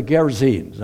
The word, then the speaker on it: Gerasenes: And it says this: they came to the other side of the sea in the country of the Gerasenes. Now, you can pronounce that word several different ways, but that's pretty much the way it's pronounced.